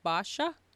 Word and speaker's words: Basia: You can call me Basia. Like Basha. – Say it again. Sorry. – Like it, the way you would pronounce it, like you would Basha. – Like Basha? – Yes, exactly.